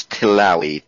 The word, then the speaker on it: Tillawi: Spelling it's the first name is Hisham, H-E-S-H-A-M, and the last name is Tillawi, T-I-L-L-A-W-I.